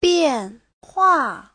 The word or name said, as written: bian4hua4